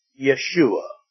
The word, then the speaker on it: Yeshua: In the Hebrew, it would be Yeshua. We know it in our English translation as Joshua. In Spanish, the J is pronounced as an H, but in Hebrew, it's pronounced as a Y.